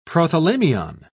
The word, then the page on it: prothalamium: British English